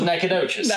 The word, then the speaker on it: Nacogdoches: Well, it was Nacogdoches.